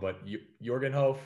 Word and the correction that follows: Jorginho: uh, defender, uh, midfielder. I could I could be pronouncing his name wrong. – Yes. Oh, Jorginho. Yeah. – Jorginho.